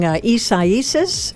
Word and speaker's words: Isaias: I'm saying Isaias, I believe is the correct pronunciation.